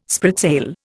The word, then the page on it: spritsail: Indian